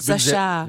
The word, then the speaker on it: Sacha: Aber Sascha, da geschrieben steht Sacha. – Ja, ist eine Variation. Okay. Kannte ich auch am Anfang Französisch. – Nicht. – Französisch, Sascha.